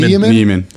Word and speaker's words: Niemann: Niemann? How do you say his name? – Niemann.